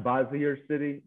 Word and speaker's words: Bossier City: Bossier City.